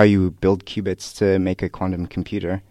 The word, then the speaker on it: qubit: But I saw the word qubits, and the only way I know of qubits is like how they measured Noah's Ark, and that can't possibly be right.